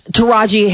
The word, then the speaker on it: Taraji: Is that, how do you say her name, Tarari Henson? – Taraji Henson.